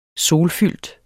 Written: solfyldt

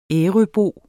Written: ærøbo

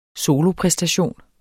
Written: solopræstation

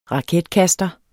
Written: raketkaster

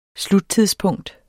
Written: sluttidspunkt

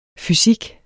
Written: fysik

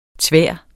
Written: tvær

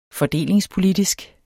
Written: fordelingspolitisk

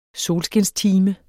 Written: solskinstime

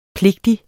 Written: pligtig

